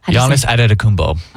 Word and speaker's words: Giannis Antetokounmpo: The attention has shifted squarely to the picks after the Giannis Antetokounmpo domino fell late Monday night. How do you say that? – Giannis Antetokounmpo.